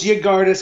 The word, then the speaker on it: Yggdrasil: Admittedly, my pronunciation here might be complete nonsense, but Gardas, I'm assuming the Y at the start is silent. – No, it's Yggdrasil, because it's a lot like Yggdrasil, which is the world tree from Norse mythology.